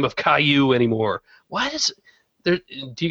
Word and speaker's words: Caillou: I mean, I don't hate Caillou any more than I hate all the other stuff my kids watch.